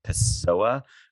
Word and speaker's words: Pessoa: So she is a Brooklynite who wrote her comparative literature PhD dissertation on the poet Fernando Pessoa, who was so intimidating to me. I don't even know if I said his name right. Is that how you say it? – Perfectly.